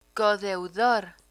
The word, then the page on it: codeudor: spanish